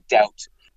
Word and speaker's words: doubt: So that's also, by the way, why we have a silent B in the word doubt.